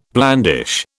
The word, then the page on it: blandish: British English